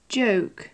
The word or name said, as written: joke